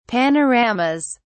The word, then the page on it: panoramas: British English